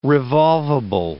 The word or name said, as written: revolvable